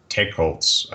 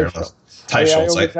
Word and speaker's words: Teicholz: I had a chance at the Paleo FX conference to meet with Nina Teicholz. I always say Teicholz. I've known Nina for 10 years, and I realized last week that I mispronounced her name, but it's Teicholz.